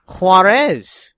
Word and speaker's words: Juarez: How are you supposed to say it? – Juarez, if you want to make our fans happy. Everything has to be pronounced with an accent.